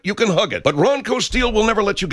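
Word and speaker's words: Ranko: It's spelled R-A-N-K-O. R-A-N-K-O, but pronounced Ronco steel.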